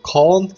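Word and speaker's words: Kean: Moises Keane som uttalas Moises Kahn som de säger.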